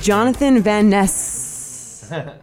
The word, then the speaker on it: Jonathan Van Ness: And we're back with Jonathan Van Ness. Is this how I learned his name is pronounced? Right? You have to linger on the S? – Linger on the S.